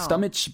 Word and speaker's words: stomach: 네, stomach 발음하시면 안 돼요. stomach. – stomach가 아니라 stomach라는 발음이 정확한 발음이라는 것까지 짚어주셨어요.